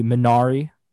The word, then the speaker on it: Minari: Like the production company of Midsommar and Hereditary are coming out with a new movie called Minari, which I guess if you were to pronounce it in English, it would be Minari.